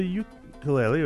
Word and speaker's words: ukulele: Well, the ukulele, or the ukulele, as you're saying, the Hawaiian pronunciation, the correct pronunciation, I should say.